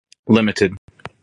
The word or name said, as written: Ltd.